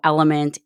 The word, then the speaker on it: LMNT: As you know, Element is an elite hydration pack. So it's spelled LMNT, but it sounds like Element.